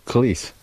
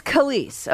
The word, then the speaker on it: Kelis: Um, did you figure out how we pronounce Kelis? – Oh yeah, it's um, Kelis. – It, oh, it is Kelis. – Okay, mm-hmm. K E L I S.